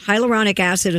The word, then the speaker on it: hyaluronic acid: Is it pronounced hyaluronic acid? – Hyaluronic acid.